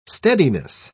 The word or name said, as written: steadiness